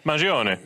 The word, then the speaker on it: Mangione: Am I saying that right? – Mangione. Yeah, Mangione. – Mangione. – Yeah, yeah. I always say it's like Joni Mitchell. Mangione.